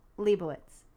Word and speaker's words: Lebowitz: Is it Lebowitz? – Lebowitz. Thank you.